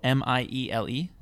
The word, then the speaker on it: Miele: It's called the I don't know how to pronounce this. It's a German vacuum cleaner, but it is uh, Mile, M I E L E. – Miele.